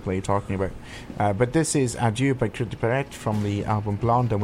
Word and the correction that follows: Cœur de pirate: Sebastian's laughing at me because I butchered the pronunciation again. – Cœur de pirate. – Excellent, de pirate, yeah.